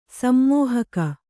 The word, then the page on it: ಸಮ್ಮೋಹಕ: sammōhaka